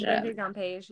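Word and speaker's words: Regé-Jean Page: Because, obviously, we know that I cannot pronounce his name. – Oh, Anthony? – No. Regis Jean Page. – Oh, Regis Jean Page, yeah.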